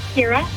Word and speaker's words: Kira: Kira.